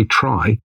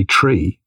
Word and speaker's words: trie: So you say try, and I say tree. You're probably right, but I'm sticking to tree. – Well, it is spelled T-R-I-E. So to make the distinction between the tree, like the T-R-E-E, you know.